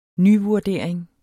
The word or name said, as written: nyvurdering